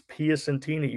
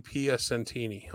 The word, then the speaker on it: Piacentini: It's Piacentini. You added an N, but that was just about perfect. – Piacentini.